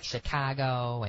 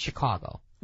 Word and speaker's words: Chicago: They say, like, Chicago, and it's just wrong. It's just Chicago.